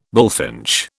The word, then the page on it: bullfinch: British English